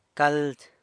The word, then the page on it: cald: San Lucas Quiaviní